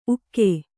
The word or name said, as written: ಊಕ